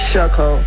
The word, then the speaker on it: Shacole: Our guy, Big Med, said that your name is Shay Cole. Is that correct? – It's Shacole. – Shacole. – How you spell it? Because I'm trying to figure this out. – He said it like Shay Cole, like J. Cole. – Right. – How you spell your name? – S-H-A-C-O-L-E.